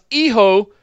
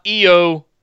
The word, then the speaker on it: hijo: He says, it's pronounced Iho, not Eo or Hijo. – Iho. – It is, and you don't have to say the L every in front of it.